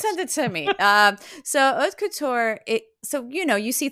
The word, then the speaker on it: haute couture: Haute couture. So haute couture. – You don't really pronounce age.